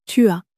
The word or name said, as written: tu as